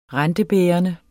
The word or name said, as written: rentebærende